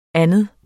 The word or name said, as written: anden